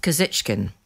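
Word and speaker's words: Kuzichkin: Tell us about Kozichkin. That's how I pronounce it, isn't it? – That's right. That's exactly right.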